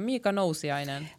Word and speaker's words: Nousiainen: Jättebra, Mika Nousiainen.